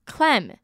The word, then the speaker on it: clam: 那中间 ai 跟 a 的发音不太一样，我在这边强调一下，宣称是 claim ai 发。 A 的音，那蛤蜊 clam A 呢自发 A 的音 A 跟 A claim clam，这样有没有搞懂？